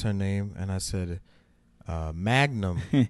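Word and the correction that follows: Mangum: instead of Man Gum, which is her. – Oh shit! – Which is her last name.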